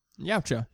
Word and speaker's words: Yautja: Yautja, yeah.